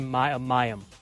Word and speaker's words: Mayim: Ken Jennings going to have to split hosting duties on Jeopardy with Maheem Bialik. Did I say that right? – I believe Mayim. – Yeah, Mayim Bialik.